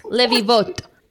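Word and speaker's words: Livivot (לביבות): How do you say latkes in Hebrew? It starts with a lamed also, le. – Livivos. – Livivos. Our lakas. Livivos. Livivot. If you were in Israel, you would say livivot.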